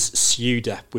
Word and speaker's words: SUDEP: So I just want to introduce an acronym into the conversation that Beverly mentioned briefly in episode one, which is SUDEP, which is spelled S-U-D-E-P.